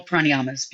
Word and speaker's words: pranayama: So that's pranayama. Is that right? Have I said that right? – Beautiful. Beautiful. Okay. Pranayama. Pranayama.